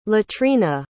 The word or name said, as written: latrina